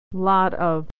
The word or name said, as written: lot of